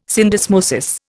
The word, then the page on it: syndesmosis: Indian